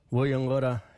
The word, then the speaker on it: Buyungurra: Ngada gamaru Buyungura yugunga. I cooked a turtle yesterday. Buyungura is spelled B-U-Y-U-N-G-U-R-R-A.